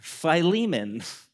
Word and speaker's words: Philemon: Um, yeah, filet mignon, Philemon.